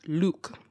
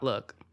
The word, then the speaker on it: lewk: spelled L-E-W-K, pronounced Luke, not look.